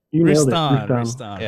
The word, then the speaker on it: Routan: Rustan. Yeah, perfect. – Perfect. – I mean, it's actually like Rouston, like there's like a French twang to it, but I say it wrong.